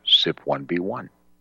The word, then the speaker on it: CYP1B1: CYP1B1, pronounced SIP1B1.